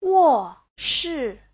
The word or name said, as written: wo4shi4